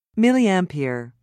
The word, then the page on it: milliampere: American English